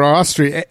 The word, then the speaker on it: Astrid: It's spelled like Astrid and Ensign like Ensign. It took me a couple of years to be able to figure out how to say her name properly, because both sides of it are not the way you would think.